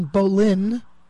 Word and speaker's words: Boleyn: And then the next daughter, who he married, Anne, decided she preferred the French pronunciation, Boleyn.